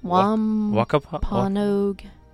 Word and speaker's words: Wampanoag: Wapa, Pogue. Oh, Lord. I wish there was... It begins with a W. It's a long W name. – Like a... – Wampanoag.